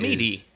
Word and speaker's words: Meade: Okay, so the M-E-A-D-E, what I've found is... – Meady. – Right, meady. Fort Meady.